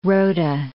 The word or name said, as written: rota